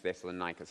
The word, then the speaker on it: Thessalonica: So the only question I'm going to ask you, and I just want you to think about it, is how do you pronounce this word? What is this? A harmonica? No. Harmonica. Rhymes with Thessalonica.